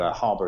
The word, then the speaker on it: Harbour: And Harbour is spelled the British way, so it's HARBOUR at the end.